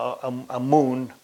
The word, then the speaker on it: Amun: Now, the hymn, there's a hymn actually to Amon, decreed that no god came before him, Amun. It's A-M-U-N.